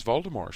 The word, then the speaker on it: Voldemort: Even now, if you listen to interviews with her, and when she says Voldemort, she says Voldemort. She doesn't pronounce the T.